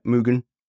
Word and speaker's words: Mugen: Am I pronouncing that right? Mugen. – As far as I know.